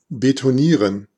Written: concrete

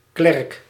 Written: clerk

